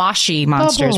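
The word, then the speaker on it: Moshi Monsters: Oh, those mushy monsters. They're actually like moshy monsters. Bubbles. We're supposed to, we should know better to say it correctly.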